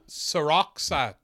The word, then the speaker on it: Seroxat: Yeah, the secrets of, Seroxat. – Oh, right, I was way off, Seroxat. Seroxat. Seroxat? – Yeah. – Seroxat. – So it's about this... – Seroxat to me, baby.